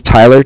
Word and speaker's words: Tyler: So, my name is Tyler, T-Y-L-E-R, and I'm calling with Cardiff. It's spelled C-A-R-D-I-F-F, as in Frank.